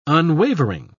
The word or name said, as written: unwavering